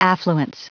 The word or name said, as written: affluence